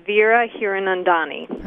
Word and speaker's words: Vera Hiranandani: It's Vera Hiranandani.